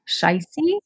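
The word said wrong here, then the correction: Scheiße: Uh, Shaisa. – Yeah, Shaisa.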